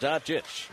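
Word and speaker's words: Dončić: Pronunciation counts in radio, not spelling. Doncic hits both free throws. That's pronounced Doncic.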